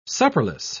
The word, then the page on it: supperless: British English